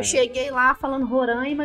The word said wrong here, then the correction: Roraima: e o pessoal, não tem acento. – Falei, beleza, como é que é? O Roraima. Agora eu tô falando certo.